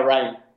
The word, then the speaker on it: Rime: El, juego, eh, espero que, creo que sí está en Xbox, eh, se, llama, se, llama Rhyme, o sea, se escribe Rime, R-I-M-E.